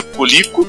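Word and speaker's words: Coleco: A outra, que era uma empresa que também não tinha nada a ver com a história de computadores, que era a Colico, é, se pronuncia Colico, Colico Industries, que é a Connecticut Litter Corporation, que a gente já falou dela em algum episódio que agora não me lembro.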